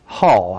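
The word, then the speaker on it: hāl: Our English words comes from the old English word hell, hall, I should say it for the good pronunciation, H-A-L, hall, which means whole. Health means whole. And interesting, the word hall, H-A-L, is also the root word for the word holy.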